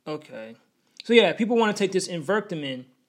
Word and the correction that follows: Ivermectin: Ivermectin, not even saying it correctly, Ivermectin, they want to take the Ivermectin as a substitute for the vaccine.